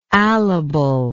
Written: alibility

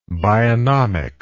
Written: bionomist

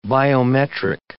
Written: biometric

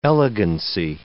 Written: elegancy

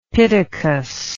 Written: pittacus

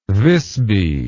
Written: Wisby

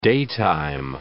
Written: daytime

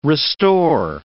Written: restore

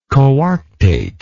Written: coarctate

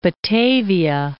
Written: Batavia

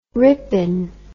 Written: Ripon

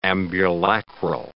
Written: ambulacral

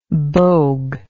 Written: bogue

